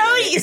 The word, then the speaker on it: Chode: This is my beautiful baby dog Chode. Pronounce Cody, by the way. – Cody, C-H-O-D-E, Cody.